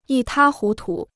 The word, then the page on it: 一塌糊涂: yī tā hú tu